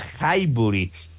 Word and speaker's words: Highbury: Estaba en proceso de levantar planos y más, porque ahí se jugaba en un estadio que se llamaba Highbury.